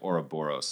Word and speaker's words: Ouroboros: it's to me, it's more it's almost more like Ouroboros.